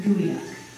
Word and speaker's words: geoduck: It may be spelled geoduck, but it's pronounced goey duck.